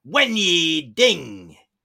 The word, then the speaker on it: Wen Yi Ding: Lucas Herbert, David Micheluzzi, Kazuma Kobori, Denny Wing. Sorry, I got so excited there. I said his name wrong. Wen Yi Ding. Ding dong, Wen Yi Ding.